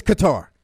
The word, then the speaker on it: Qatar: It's Qatar.